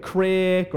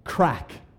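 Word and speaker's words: craic: Now, how would you assume that this word is pronounced? Right, so a lot of people say like crick or crike or whatever. The way that this word is pronounced, as Irish people, we say crack, which means something entirely different in America, right?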